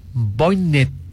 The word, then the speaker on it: Voynich: Y también tenemos por otro lado este misterio, este caso sobre el manuscrito Voynich, así se pronuncia.